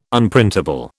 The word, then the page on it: Unprintable: British English